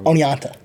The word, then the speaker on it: Oneonta: Oneonta.